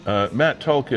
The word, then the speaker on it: Matt Tulk: Matt Tulk is the Director of Membership for GAMRU.